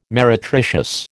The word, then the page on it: meretricious: American English